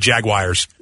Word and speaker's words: jaguar: There are NFL announcers that refer to them as the Jaguars. – No, it's jaguar. – But the word you're saying is J-A-G-W-I-R-E. That's not how it's said at all.